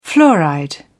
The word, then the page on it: fluoride: British English